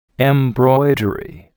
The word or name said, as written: embroidery